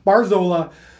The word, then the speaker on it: Barzola: Sorry. Barzola. – I never wonder. – No, it's right. – When it comes to any kind of anything, if it's in South America, I'm like, I don't know the pronunciation. – No, you're right. Enrique Barzola.